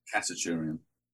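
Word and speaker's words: Khachaturian: Is that right? How you pronounce it? – Kachaturian. – Kachaturian. That's the one.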